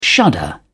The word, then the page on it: shudder: British English